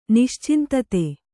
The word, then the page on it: ನಿಶ್ಚಿಂತತೆ: Kannada